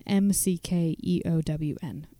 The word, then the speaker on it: McKeown: It, maybe you could spell that because oh sure, E R I N M C K E O W N.